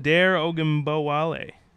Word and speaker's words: Dare Ogunbowale: How do you pronounce that? – Dare Ogunbowale. – I thought I was like, wait a minute. – It's probably like Dari Ogunbowale, but again, okay.